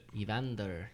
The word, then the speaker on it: Evander: Evander.